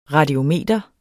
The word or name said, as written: radiometer